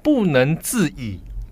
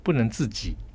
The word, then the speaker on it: 不能自已: 我们说一个人可能没办法控制呢，叫做不能自已。 – 对啊，那你念成什么？ – 不能自己。